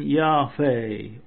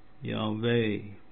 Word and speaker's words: Yahweh: The true pronunciation seems to have been Yahweh or Yahweh. The initial I equals Y as in Yakimo. The final E should be pronounced like the French E with an arrow, or the English E in there. And the first H sounded as an aspirate. The accent should be on the final syllable, Yahweh.